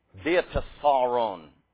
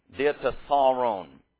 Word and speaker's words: Diatessaron: Diatessaron is the way that you say this word. So the accent is on the penult. The penult is the syllable before the ultima, the last syllable. So it's the second to last syllable.